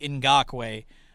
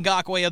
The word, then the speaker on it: Ngakoue: It's spelled N-G-A-K-O-U-E, Yannick Ngakoue. I will say I love hearing Rick pronounce his name. He was a Raider, I think, for a year. I think he calls him Ngakoue. Like it's just, I'm like, that's very much not it.